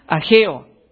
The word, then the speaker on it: Ageo: Ageo, gracias. Nunca he pronunciado, obviamente no lo pronunció bien. ¿Cómo dice? Ageo. Ok, Ageo, si alguien quiere leer, capítulo 1, versículo 2 a 7.